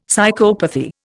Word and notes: psychopathy: Indian

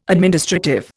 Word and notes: administrative: Indian

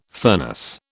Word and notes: furnace: British English

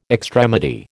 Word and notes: extremity: American English

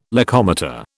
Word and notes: locomotor: British English

